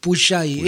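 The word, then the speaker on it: Pujllay: Puyay con J antes de la doble L. Termina con Y, es Puyay.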